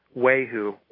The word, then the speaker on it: Ywahoo: It's Dhyani Wehu, Y-W-A-H-O-O.